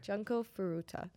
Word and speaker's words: Junko Furuta: Yeah, I believe it's, I don't know if it's Junko or Junko. Junko? Junko. – Junko? Okay. – Junko Furuta. Okay. That sounds right.